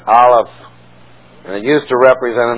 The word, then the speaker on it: Aleph: It's pronounced Aleph.